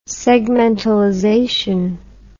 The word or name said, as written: segmentalization